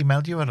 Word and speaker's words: Katie Melua: Is Katie Melua or Katie Melua? – Melua.